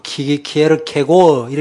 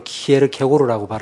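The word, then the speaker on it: Kierkegaard: 뭐 키에르 케고 이렇게 발음하는 분들도 계시던데 어쨌든 간에 일반적으로 키에르 케고르라고 발음하는데 키에르 케고르가 진리가 무엇이냐라는 이 빌라도의 질문은 태초 이래 인간이 질문할 수 있는 가장 어리석은 질문이라고 말했습니다.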